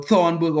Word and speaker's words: Thunberg: Greta Thunberg. Mm. Or Thunberg, or whatever the hell you pronounce her name.